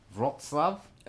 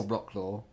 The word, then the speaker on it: Wrocław: now the pronunciation... Wrocław? – Oh, you can say it, yes. – Or Wrocław. – Yes.